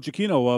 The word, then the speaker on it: Giacchino: Is it Giacchino? Is that how you pronounce his name? – Yeah, Michael Giacchino of uh, Lost fame.